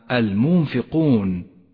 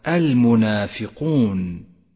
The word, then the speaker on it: المنافقون: فإذا أهملت نطقها ربما قرأت الكلمة المنفقون ولكن إذا لاحظتها وقرأتها بشكل صحيح غدت الكلمة المنافقون